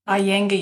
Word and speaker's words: Iyengar: Iyengar yoga.